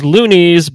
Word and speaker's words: Luniz: I believe the actual proper pronunciation is Lunis, but it is spelled L-U-N-I-Z.